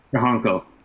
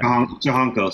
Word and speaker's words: Tiojanco: Tiojanco. – Tiojanco. Oh, man. – Tiojanco. So the T-I-O is pronounced Cho. – Oh. – Yeah. Great. And then J-A-N is Han, and then C-O is Cho.